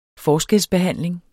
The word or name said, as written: forskelsbehandling